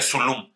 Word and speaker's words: Dzulum: También estaba otro güey que se llamaba Zulum, es D-Z-U-L-U-M, pero eh, creo que se pronuncia Zulum.